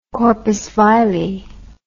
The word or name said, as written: corpus vile